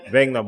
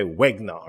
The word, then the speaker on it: Wenger: Wenger, but they'll say Wegner or something like oh, that, right? Yeah. That's completely mispronounced the name because you know just not necessarily mm-hmm.